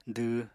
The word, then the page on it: dw: apatani